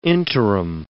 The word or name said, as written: interim